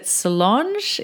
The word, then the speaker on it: Solonge: So is it Solonge? Is that how we say it? – Yes. – Yes. Awesome. So welcome to the podcast, Solonge.